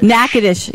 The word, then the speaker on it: Natchez: No, it's Natchez. – Natchez. Yeah, sorry, I pronounced it wrong.